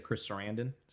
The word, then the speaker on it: Chris Sarandon: Yeah, I love when you said Chris Sarandon.